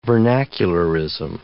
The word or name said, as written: vernacularism